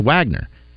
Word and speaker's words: Wegner: Well, his name was spelled like Wegner, but it's pronounced Wagner.